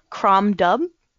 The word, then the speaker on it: Crom Dubh: Crom Dubh. – Spell the whole thing. – C-R-O-M space D-U-B-H.